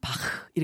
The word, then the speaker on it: 바흐: 바 우리 원래 발음으로는 바흐 이렇게 아, 불러야 되는데요.